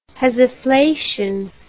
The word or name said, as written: hesiflation